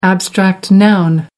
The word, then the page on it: abstract noun: American English